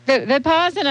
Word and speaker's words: Vipassana: Vipassana. – I had my consonants wrong. Vipassana meditation over 4 years ago.